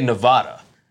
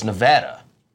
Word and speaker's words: Nevada: We used to always say Nevada, but it's Nevada, apparently.